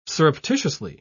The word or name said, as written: surreptitiously